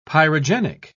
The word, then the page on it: pyrogenic: British English